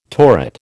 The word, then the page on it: torrid: American English